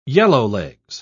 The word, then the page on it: yellowlegs: British English